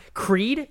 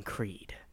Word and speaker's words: Creede: Creed, C-R-E-E-D-E. They had an E to Creed. Why? Don't need it. It's already pronounced C-R-E-E-D. You can't mispronounce that. They had an E on the end.